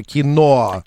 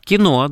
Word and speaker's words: киноа: Оказывается, в этой крупа, название этой крупы, ударение ставится киноа. – Киноа, да. – А мы киноа.